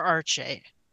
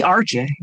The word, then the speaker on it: Arche: Maybe it is Arche. Yeah, this looks like it'd be Arche Kurain. I guess it should be Arche. Yeah. Okay. I've been saying it wrong in my head the whole time, I guess.